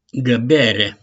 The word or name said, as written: GĂBERE